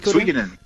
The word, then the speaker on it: Suikoden: Suikoden. Right, Suikoden.